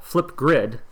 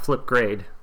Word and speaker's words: Flipgrid: The tool is called Flipgrid with an I, not Flipgrade with an A.